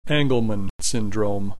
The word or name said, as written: Angelman Syndrome